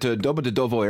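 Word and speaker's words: Domodedovo: When we were there, we landed at Dobrodovo Airport. – I'm sorry, did you stutter? – No, that's how you say it.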